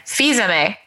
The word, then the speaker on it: Fils-Aimé: It's called Disrupting the Game: From the Bronx to the Top of Nintendo by Reggie Fils-Aimé. Is that how you say it? – Fils-Aimé. – Oh, that's right. Cause it's like Tresemme. We looked this up beforehand.